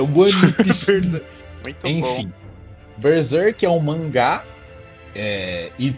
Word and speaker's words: Berserk: Eu queria fazer a recomendação de Berserk, ou para fazer a, a, pronúncia em, em japonês, Beruzeruku. Não falo japonês, como vocês já perceberam. Berserk, Berserk.